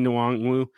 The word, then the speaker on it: Nwangwu: At running back, Kenny Nwangwu.